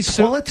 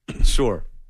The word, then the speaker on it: sewer: Say sewer. Say sewer. – Sore. – Sore? – Sore. – Sore. – Sewer. – Sore. – It's two syllables.